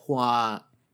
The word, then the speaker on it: Hua: Funny enough, mentioning a flower, the fact that Hua is the correct pronunciation of flower in Mandarin.